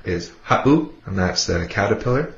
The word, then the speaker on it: ha7u: If you listen to that word ha'u, ha'u, it would be spelt H-A-7-U. If you were to take out that 7, it would be pronounced ha'u, with the 7 in there. It makes that little glottal stop, that pause. So you say ha'u, ha'u.